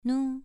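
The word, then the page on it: nu: tone 2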